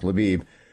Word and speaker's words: Lviv: It's pronounced Lvov. It used to be. Now it's pronounced Lviv.